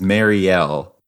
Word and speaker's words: Mariel: The audiobook makes it pretty clear that it is Mariel because it sounds like a bell.